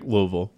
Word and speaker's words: Louisville: Well, it was Georgia Tech Louisville. – Yeah. – Louisville. You swallow it.